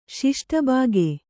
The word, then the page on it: ಷಷ್ಠಭಾಗ: ṣaṣṭha bhāga